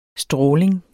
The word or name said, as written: stråling